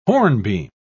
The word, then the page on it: hornbeam: British English